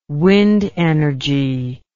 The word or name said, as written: wind energy